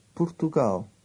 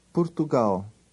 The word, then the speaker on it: Portugal: Portugal. – Repeat it aloud after him. Always repeat aloud after the speaker. – Portugal.